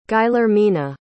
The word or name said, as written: guillermina